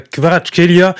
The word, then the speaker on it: Kvaratskhelia: La première parisienne de Gvicha Kvaratskhelia, meilleure prononciation cette fois, ce sera peut-être pour ce soir à 20h05 face à Reims.